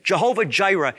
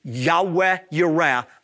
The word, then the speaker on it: Yahweh Yireh: But again, Jehovah Jireh is a mispronunciation of Genesis 22:14. It should be Yahweh Yireh, the Lord will provide.